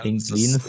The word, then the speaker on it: Englenes: Nämlich auf dem Album ähm, Die Nacht der Blasphemie im Song Englenes Fall, spricht man das so aus? Eng ja, Englenes.